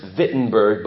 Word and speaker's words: Wittenberg: It's pronounced Wittenberg, but it looks like Wittenberg. OK. And Berg is B-E-R-G.